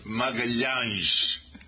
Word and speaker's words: Magalhães: Because in English, his name, his Portuguese name, he grew up in a beautiful area of northern Portugal, but his Portuguese name, we pronounce it Magalhães. And in English, Magalhães is Magellan.